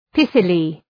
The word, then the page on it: pithily: ‘pıɵılı